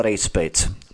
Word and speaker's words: Rejcpis: Jak se soudce Michal Rejcpis postavil za toto neoprávněné zapsání statutárních orgánů do firmy CZK+. – No, soudce Rejcpis. – Pardon, Rejcpis, evident... já to nemůžu vyslovit, jasně, Rejcpis.